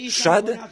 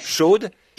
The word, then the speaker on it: Shad: En réalité, le mot hébreu Shad, S-H-A-D, certains le prononcent Shod, Shad signifie saint, comme un saint de femme.